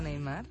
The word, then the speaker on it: Neymar: No, el único fallo que tiene Neymar yo creo, Neymar, lo digo bien.